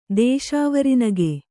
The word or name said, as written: ದೇಶಾವರಿನಗು